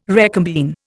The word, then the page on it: recombine: Indian